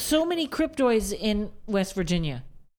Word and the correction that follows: cryptids: The cryptids.